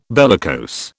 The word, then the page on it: bellicose: British English